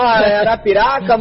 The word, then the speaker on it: Arapiraca: Eita, ô, Nery, você que nasceu em Arapiroca, não, Arapiraca, mano, de novo falei errado.